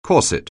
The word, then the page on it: corset: anglais